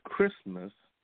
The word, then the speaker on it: Christmas: And so even today we say Christmas, and it's pronounced without the T, but it is a Christ Mass.